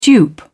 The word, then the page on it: dupe: British English